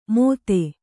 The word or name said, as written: ಮೋದೆ